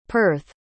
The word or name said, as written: perth